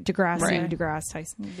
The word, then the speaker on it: Neil deGrasse Tyson: deGrasse Tyson.